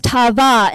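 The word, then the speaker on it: tavah: That word in Hebrew is pronounced tava, and it means to grieve, to cause pain, but also to set limits.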